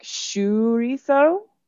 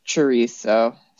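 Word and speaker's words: chouriço: And I guess in Portugal, it's a little bit more like chorizo. It's a more of an S sound, but pretty close to chorizo. So chorizo. I might even say it like that.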